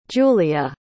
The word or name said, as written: giulia